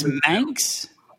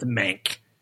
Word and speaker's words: Mank: How is it pronounced? It's Manx? – It's Mank. Mank. – Mank, like Mankiewicz.